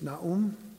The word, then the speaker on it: Nahum: Nahum, sometimes it's pronounced, but it's Nahum.